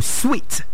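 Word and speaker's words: suite: S-U-I. No, it's not suit. That's what you think, actually. But the correct pronunciation is suit. Say suit. Can you say suit? Suit. No, sweet. Sweet. – Sweet. – No, sweet. Sweet. That's the way it's pronounced.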